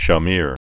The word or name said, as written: Shamir, Yitzhak